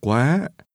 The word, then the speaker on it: quá: 18 đô la nhiều quá. Quá. Quá. Nhiều quá. 18 đô la nhiều quá. – The Vietnamese word too, as in too much, is pronounced with a rising tone.